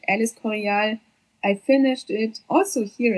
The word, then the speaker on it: El Escorial: El Escorial.